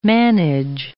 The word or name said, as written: manage